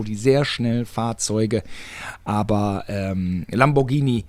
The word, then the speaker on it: Lamborghini: Lamborghini sagt man auch immer ganz gerne, aber es ist das Italienische. – Ich hätte das gesagt. – Ja, man spricht man ja mit G aus, das Italienische. Ah, da kann man ja noch drauf kommen. Es gibt ja durchaus Leute, die Italienisch sprechen, Lamborghini.